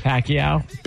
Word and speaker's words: Pacquiao: That's, how are you supposed to say his name? – Pacquiao.